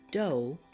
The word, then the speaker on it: dough: But here are two words where the GH is silent. Let's say these together. Dough and way. You don't hear the H sound because it is silent.